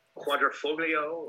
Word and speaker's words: Quadrifoglio: How do you pronounce it? I'm going to butcher it. The Quadrifoglio. – That's like pretty good. Yeah, I think you're right.